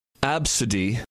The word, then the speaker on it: Abcde: Topping the list, this name is pronounced Absidy, but it is spelled A-B-C-D-E.